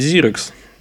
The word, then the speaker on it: Xerox: Произносится, кстати, ведь зирекс, ну, если на тот манер.